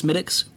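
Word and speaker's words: Smithwick's: It's pronounced Smittix. It's spelled Smith Wicks, which is exactly like Maniac Mansion.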